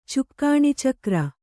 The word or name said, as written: ಚುಕ್ಕಾಣಿಚಕ್ರ